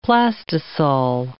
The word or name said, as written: plastisol